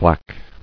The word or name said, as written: Blacks